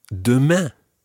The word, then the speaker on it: demain: And it's really important that you try to get that nasal sound at the end of demain. Demain. – Demain. – Demain. Très bien. It's not à demain and then leave it open. You've got to finish it off with a nasal sound.